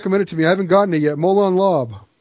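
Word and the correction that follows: Molon labe: Molon LeVay, actually, is how it's pronounced. Molon LeVay. LeVay, depending on which version of Latin you speak, the Old Latin or New Latin.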